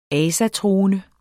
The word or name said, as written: asatroende